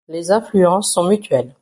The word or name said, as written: mutuelles